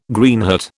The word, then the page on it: Greenheart: British English